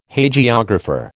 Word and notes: Hagiographer: American English

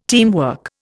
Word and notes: teamwork: Indian

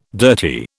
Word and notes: dirty: British English

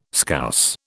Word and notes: Scouse: British English

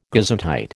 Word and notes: gesundheit: American English

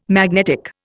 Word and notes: magnetic: Indian

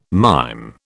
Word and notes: mime: British English

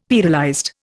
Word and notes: pearlized: Indian

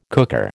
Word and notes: cooker: American English

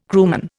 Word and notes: crewman: Indian

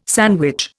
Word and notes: sandwich: Indian

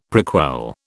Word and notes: prequel: British English

